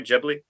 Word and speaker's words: Ghibli: Ghibli? – Yeah, Ghibli.